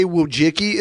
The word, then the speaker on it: Wojcicki: A lot of people say Wojcicki. It's Wojcicki, but he said Wojcicki.